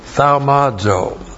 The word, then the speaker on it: thaumazo: T-H-A-U-M-A-Z, long O, thaumazo.